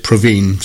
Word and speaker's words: Provine: I think she pronounced it Provine, so I better go with that one.